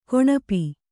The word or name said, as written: ಕೌಣಪ